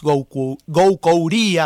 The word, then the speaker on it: Goucouría: Han estado poetas de aquí de la ciudad de Santa Fe, como por ejemplo Laura Kiener, Jesús Goucouría, así se pronuncia.